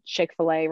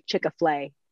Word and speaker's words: Chick-fil-A: Kids say Chick-fil-A wrong, and I don't think they'll ever say it correctly. They say Chick-a-flay, like since they're babies.